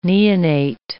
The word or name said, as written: neonate